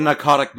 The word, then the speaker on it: Pnakotic: It's spelled P-N-A-K-O-T-I-C, but given the P-N, you know, convention in English, I would chalk that up to it being pronounced narcotic, kind of like how Don said.